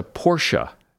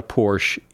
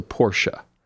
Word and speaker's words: Porsche: The car is a Porsche. It is not a Porsche. It is a Porsche.